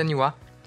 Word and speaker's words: Niwa: Olha só, a gente acertou o nome dela, que se escreve N-I-W-A, né? E se fala Niwa.